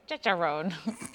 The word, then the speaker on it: Turkestrone: Turkestrone, like turkey with testosterone.